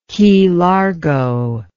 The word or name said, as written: Key Largo